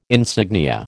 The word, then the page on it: insignia: American English